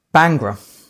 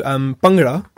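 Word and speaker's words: Bhangra: So Bangra, as it said, like more traditionally, which is quite hard to pronounce because it's a B and a H and you kind of think, how do you say that?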